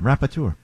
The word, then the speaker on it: rapporteur: R-A-P-P-O-R-T. – Hold on. For National Dyslexic Week, go ahead. – R-A-P-P-O-R-T-E-U-R. – Rapporteur. You're close. – All right, good. – Yeah. No, say it again. – Rapporteur. – I think you're correct. – Nice. – Yeah, Rapporteur.